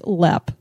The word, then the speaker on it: Leap: Denise, I know that when we were telling people we were going to do the show in the last podcast, we said Leap Castle. It's actually pronounced Lepp. – I know, we figured that out. And so, but it is spelled like leap, like a frog would leap.